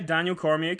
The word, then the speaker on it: Cormier: It was like, he comes, you know, is it Daniel Cormier? Is that how you pronounce his name? – Yeah, Daniel Cormier, correct.